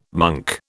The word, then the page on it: monk: British English